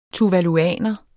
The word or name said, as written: tuvaluaner